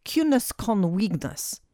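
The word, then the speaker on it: ciúnas gan uaigneas: It's pronounced something like cunus con wignus, although you'd never know that from the spelling, which means quietness without loneliness.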